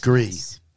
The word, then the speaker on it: Gris: Gris. Correct. – Don't pronounce the S. – S is silent.